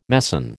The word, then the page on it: meson: American English